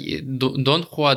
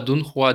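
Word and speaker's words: дунхуа: Который не аниме, который Дон Хуа, или как это правильно произносить? – Там, да, Дон Хуа, Дун Хуа, Дунь Хуа, очень много вариантов.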